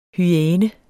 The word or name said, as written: hyæne